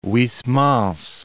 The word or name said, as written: Huysmans